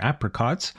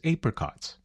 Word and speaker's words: apricots: That was Maya Cumming, a Sydney, Australia native who records as Maya, spelt M-A-Y-A. Now, I would pronounce the song name Apricots, but I guess the Aussie pronunciation is Apricots.